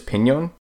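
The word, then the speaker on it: piñón: An N with a tilde on top is an enya and is pronounced with a sound, such as pinon.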